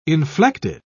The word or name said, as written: inflected